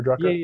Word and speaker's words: Drucker: So I noticed on your website that you said you guys learned a lot from Peter Drucker. Is that how you pronounce his name? Peter Drucker? – Yeah, Drucker.